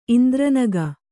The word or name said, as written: ಇಂದ್ರನಗ